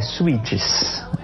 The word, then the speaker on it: Suits: se chama Suits. Ela, se você não entendeu o que eu falei, é Suits. É, se escreve Suits.